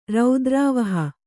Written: ರೌದ್ರಾವಹ

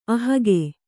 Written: ಅಹಗೆ

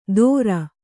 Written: ಥೋರ

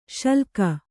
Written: ಶಲ್ಲಕ

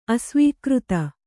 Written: ಅಸ್ವೀಕೃತ

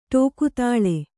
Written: ಠೋಕತಾಳೆ

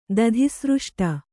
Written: ದಧಿಸೃಷ್ಟ

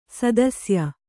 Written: ಸದಸ್ಯೆ

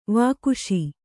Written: ವಾಗೀಶ

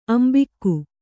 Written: ಅಂಬಿಕ್ಕು

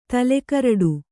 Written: ತಲೆಕರಡು

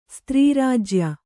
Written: ಸ್ತ್ರೀರಾಜ್ಯ

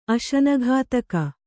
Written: ಅಶನಘಾತಕÀ

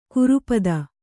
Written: ಕುರೂಪತೆ

